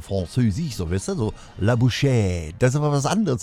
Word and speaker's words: la bouche: In Französisch, da wird die wohl so ausgesprochen. Wir lauschen mal. Ich nehme mal das Bett extra runter, damit es aufmerksamer wird, also deutlicher wird. Ne? Nicht, was das Bett im Hintergrund hört. Ich ziehe es mal runter und dann lauschen wir mal, wie das ausgesprochen wird.